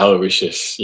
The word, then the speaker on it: Aloysius: And am I saying this right? St. Aloysius? Is that right? – Aloysius, yeah.